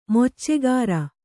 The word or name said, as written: ಮೊಚ್ಚೆಗಾರ